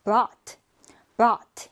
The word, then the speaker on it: brought: 자, 이거 발음 주의해 주세요. brought 이렇게 아처럼 발음해 주시면 돼요.